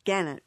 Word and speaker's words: gannet: It's called a gannet. Rhymes with Janet is the name of the bird.